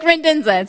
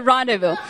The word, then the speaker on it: Rendezvous: A Buick Rendenza. It's a rendezvous.